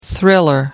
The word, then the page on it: thriller: American English